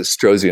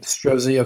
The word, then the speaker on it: Strozzi: It's called, they pronounce it Strozzi Institute. – Strozzi.